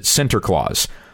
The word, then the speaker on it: Sinterklaas: You pronounce it Sinterklaas.